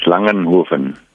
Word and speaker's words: Langenhoven: It's Langenhoven.